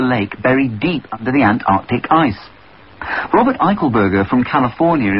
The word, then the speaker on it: Antarctic: The program host really won my heart with his correct pronunciation of Antarctic. There is a C after the R.